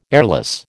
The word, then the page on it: airless: American English